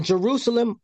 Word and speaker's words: Jerusalem: who build up Zion with bloodshed and Jerusalem with iniquity.